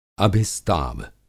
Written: अभिस्ताव

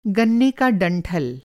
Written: डंठल

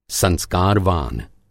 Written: संस्कारवान

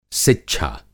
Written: सिच्छा

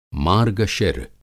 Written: मार्गशिर

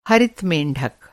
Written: मेंढक

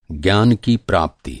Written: प्राप्ति

